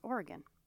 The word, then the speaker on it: Oregon: And Oregon, don't get me started. It's not, how do you say it wrong? Oregon. It's Oregon.